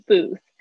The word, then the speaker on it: booth: Telephone booth，这个 booth 就是有这种小亭子、小摊位都可以叫 booth。注意一下 th 的发音，它是那个，嗯，舌尖轻轻咬住，然后发出的声音。